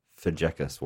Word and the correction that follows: Fazekas: way. Was that close? – Fazekas.